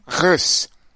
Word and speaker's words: Reus: It's not Reus at all. It's something like, you know what? I've already butchered his name. Let me try to recreate for you how you're supposed to say Reus. Reus is actually called Rus.